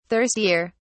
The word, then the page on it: thirstier: British English